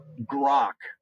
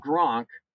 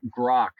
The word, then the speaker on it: Grok: So it is called Grok, G-R-O-K. And I went online. I want to make sure I was pronouncing that correctly. So it's Rock with the G, not Gronk, like the football player that retired, but Grok, like Rock.